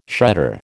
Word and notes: shredder: American English

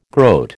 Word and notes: groat: American English

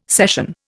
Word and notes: session: Indian

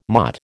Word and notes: motte: American English

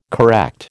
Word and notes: correct: American English

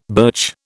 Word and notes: birch: British English

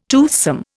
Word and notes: toothsome: Indian

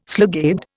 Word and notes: slugabed: Indian

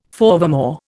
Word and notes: forevermore: Indian

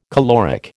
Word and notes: caloric: American English

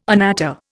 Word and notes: anatta: Indian

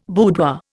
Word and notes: boudoir: Indian

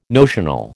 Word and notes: notional: American English

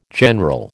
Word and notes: general: American English

